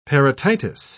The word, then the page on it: parotitis: British English